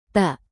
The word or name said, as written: tak